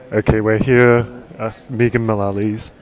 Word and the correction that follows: Megan Mullally: She's Megan.